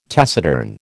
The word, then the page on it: taciturn: American English